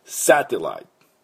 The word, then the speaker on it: satellite: サテライト、サテライト。